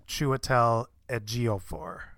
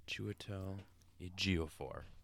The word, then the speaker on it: Chiwetel Ejiofor: Chew, like a choo-choo train. – Uh-huh. – Chiwetel Ejiofor. – Chiwetel Ejiofor.